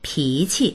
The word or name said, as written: pi2qi